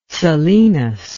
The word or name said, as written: selenous